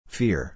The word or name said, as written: fear